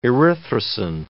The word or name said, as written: erythrosin